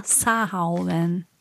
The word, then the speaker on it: Samhain: Also es wird Samhain geschrieben, komischerweise. – Ja, also wenn das irisch ist, dann ist ja die Aussprache und die Schreibweise immer recht unterschiedlich.